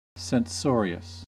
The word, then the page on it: censorious: American English